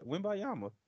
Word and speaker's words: Wembanyama: Wembanyama. It's not hard. Wembanyama.